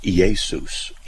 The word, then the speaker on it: Iesus: And yet Jesus is just Iesus, Iesus. It's Iota, Eta, E-A. So it's an I-A, but it's Iesus. And that's how you pronounce it in Greek.